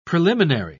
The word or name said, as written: preliminary